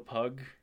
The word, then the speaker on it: Pug: So the artist here is Joe Pug, and it's spelled just like P-U-G, like the dog.